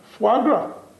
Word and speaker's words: foie gras: Foie gras, how do you pronounce it? You come from Montreal. – Foie gras. – Foie gras. Foie gras. Okay.